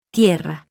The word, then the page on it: t: Spanish